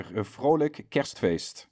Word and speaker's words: Vrolijk Kerstfeest: And I would like to wish you Vrolijk Kerstfeest. How do you say that again? – Yeah, Vrolijk Kerstfeest.